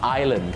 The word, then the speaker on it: island: ตัว S ไม่ต้องออกเสียงนะครับออกเสียงว่า Island ก็พ